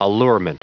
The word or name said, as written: allurement